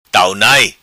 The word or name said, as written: Tau nai